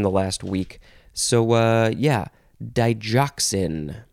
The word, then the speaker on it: digoxin: It is pronounced either digoxin or digoxin. You can pronounce the G either way. D-I-G-O-X-I-N.